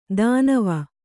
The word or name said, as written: ಧನ್ವಿ